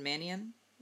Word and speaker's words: Mannion: It's Sean Mannion.